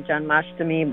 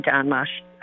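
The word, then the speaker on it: Janmashtami: I hear a lot of people in the West say John Mastami, but there's no up and down pronunciation. It would just be John Mastami.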